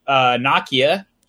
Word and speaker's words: Nokia: In other news, Nokia, which is how you're supposed to pronounce the brand Nokia.